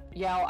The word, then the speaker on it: Yael: My name is Yael. It is hyphenated on the second syllable, Yael. – So good. – I used to say that it was like Ya as in Yahoo and L as in Ellen.